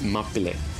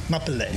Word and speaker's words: Mapile: How do you pronounce the title again? – It's Mapile. – Mapile. – Mapile. Mapile. In Tamil. Yeah.